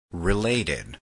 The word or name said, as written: related